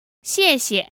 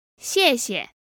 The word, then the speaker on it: 谢谢: So this word is made up of two characters, and it's actually the same character said twice, both fourth tones meaning thanks. – 谢谢。 – Now, depending on where you are, you may hear the second character pronounced with a neutral tone, giving us 谢谢。